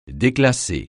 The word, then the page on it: déclasser: French